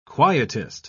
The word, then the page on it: quietist: British English